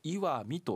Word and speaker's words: 石見: 石に見ると書いてこれで岩見と読むんですが、